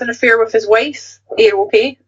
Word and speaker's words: Aerope: It's spelled Aero as in the chocolate bar. A-E-R-O and then P-E. How would you pronounce that? Aerope? – Aerope, yeah, that's nice.